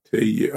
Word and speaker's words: TUI: TUI.